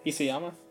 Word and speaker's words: Isayama: Isayama, yeah.